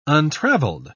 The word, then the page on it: untraveled: British English